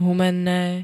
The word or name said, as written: humenne